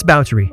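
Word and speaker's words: Báthory: Just a note, if you've ever read about Elizabeth, you might have assumed that her surname, spelled B-A-T-H-O-R-Y, is pronounced Bathory, but you'd be wrong. It's Bowtry.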